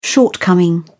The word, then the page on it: shortcoming: British English